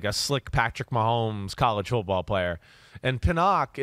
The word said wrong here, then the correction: Penix: is like, am I saying it right? – Penix. – Yep.